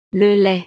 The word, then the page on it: le lait: frances